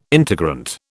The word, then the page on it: integrant: British English